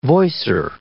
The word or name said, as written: voicer